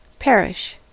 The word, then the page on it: perish: American English